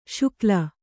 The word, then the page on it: ಶಕಲಿ: śakali